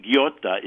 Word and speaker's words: ghiotta: Ghiotta is G-H-I-O-T-T-A. Ghiotta. When you have an H after a G, it makes it a hard G. So it's ghiotta.